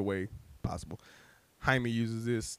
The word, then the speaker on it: Jaime: The one we know of now is Jaime Reyes, or Jaime, because that's the Spanish, that's the way that you pronounce Jamie in Spanish, is Jaime.